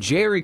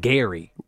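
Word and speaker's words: Gerry: I keep calling him Jerry because it's G-E-R-R-Y, and he calls him Gary.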